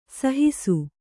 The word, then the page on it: ಸಹಸ: Kannada